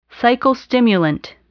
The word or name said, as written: psychostimulant